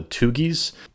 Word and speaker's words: Thuggee: There was another kind of ancient group I want to touch on called the Tugis. Now, you probably don't recognize the pronunciation I just used, Tugi, but I'm sure you'll recognize the word that we get from this group, the Thugs. This group is spelled T-H-U-G-G-E-E.